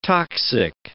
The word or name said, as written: toxic